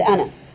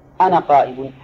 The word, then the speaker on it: أنا: طيب، وغير واجد كثير، أي واجد كثير، كما أنه قد توجد الألف ولا ينطق بها، توجد الألف ولا ينطق بها، مثل أنا، أنا قائم، هل أنت تقول أنا قائم؟